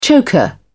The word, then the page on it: choker: British English